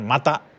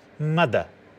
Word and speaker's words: Matter: Y, ya voy a dejar de pronunciar mata porque no es mata, es Mada. Es una cosa así como entre una T y una D, Mada.